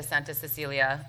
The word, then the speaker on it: Santa Cecilia: Santa Cecilia, which I'm not pronouncing quite right. – No, you're right.